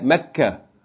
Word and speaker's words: Mecca: Fourth word, mekkatu. With no tanween, mekkatu. Pause mode, mekka. That's the way we normally pronounce it, right? Mecca.